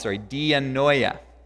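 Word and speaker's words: dianoia (διάνοια): The word that he uses is pronounced dianoia.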